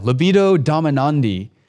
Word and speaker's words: libido dominandi: The impulse to rule others is so deep-seated that Augustine referred to it as the libido dominandi. Am I saying that right? Yeah, libido dominandi, which was the desire to dominate.